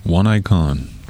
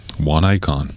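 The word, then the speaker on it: Oneikon: One Icon. – Who? – One Icon. – Ooh. Well, that name sounds interesting. – Interesting spelling, too. – Yeah? – Yeah. – Spell it. – O-N-E-I-K-O-N. N. Two N's.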